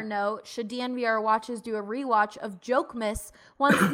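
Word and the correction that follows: Jokić: I believe it's pronounced Yoke Miss. – Yoke. Oh, yeah.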